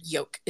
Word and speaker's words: yolk: Like, why is there an L in there? – Like, I don't like it either. – It makes yolk. Me, like, my brain goes yolk every time I yolk. Read it, but then I'm like, oh, nope, that's not how you say it.